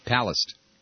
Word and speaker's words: Palast: Palast. The accent is on the first syllable.